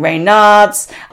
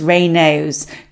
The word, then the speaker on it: Raynaud's: And let's clarify how to say Raynaud's as well, because I've heard a lot of people saying Raynards, even Reynolds, but the correct pronunciation is Raynaud's, just in case you were wondering.